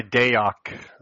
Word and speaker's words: Dayok: Yeah, I go by Dayok. I like to say it's the opposite of Nighthawk.